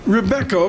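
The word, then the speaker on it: Robeco: Depends if you're Dutch. It's Rubico. – Rubico, okay.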